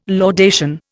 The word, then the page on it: laudation: Indian